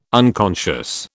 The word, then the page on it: unconscious: British English